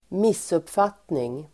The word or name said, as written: missuppfattning